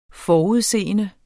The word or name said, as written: FORUDSES